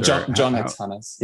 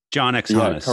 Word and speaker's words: JohnxHannes: And you started, how do you pronounce, is it Jan and Hannes, or is it Jan X Hannes? – John X Hannes, yeah. – John X Hannes.